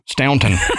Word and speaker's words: Staunton: Now, I've heard it pronounced two ways, Stanton or Staunton. What? Anyway, Staunton. – Staunton. – Staunton. – Okay. But it's, I think it's Staunton, Virginia.